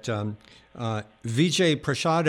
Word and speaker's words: Vijay Prashad: I should mention that Vijay Prashad, am I pronouncing that right? – Yes, yes.